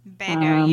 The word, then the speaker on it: Beto: He's at Beto or Beto, Beto. I don't know how to pronounce it. But B-E-T-O. – Beto unit. – Beto, thank you.